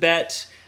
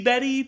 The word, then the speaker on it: Bette: So she shows up, and that's where I'm saying, like, from now on, I'm going to pronounce it Bette. It could be Betty, but it's B-E-T-T-E, okay? – All right.